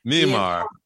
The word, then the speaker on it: Myanmar: Myanmar.